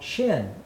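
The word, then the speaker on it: shin (שׁין): If the dot is pronouncing it sh, then it's called shin, is the name of that letter.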